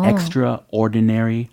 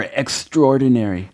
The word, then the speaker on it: extraordinary: Extraordinary 음. Mm. or extraordinary. 네, 마치 두 단어가 어, 띄어져 있는 것처럼 발음을 하실 수도 있고 아니면 아예 한 단어처럼 빠르게 발음을 하셔도 된다라는 설명을 해주셨어요.